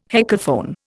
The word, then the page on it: heckelphone: Indian